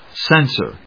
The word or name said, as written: censor